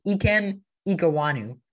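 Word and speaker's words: Ikem Ekwonu: It's just Ikem Iguanu. I feel like that's better than the majority of broadcasters have been pronouncing his name.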